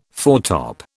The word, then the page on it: foretop: British English